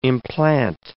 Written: implant

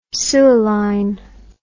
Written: suilline

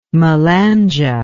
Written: malanje

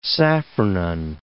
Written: safranin

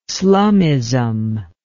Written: slumism

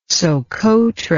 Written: Sokotra

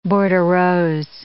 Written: bordereaux

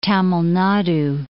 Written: Tamil Nadu

